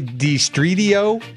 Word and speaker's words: DiStridio: Did you say DiStridio? – That's the Italian pronunciation.